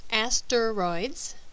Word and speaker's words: asteroids: The word asteroids can be said as three syllables, asteroids, but the E is hidden by the R vowel. This easily changes to two syllables because the middle syllable ter shifts to combine with the last syllable, making asteroids.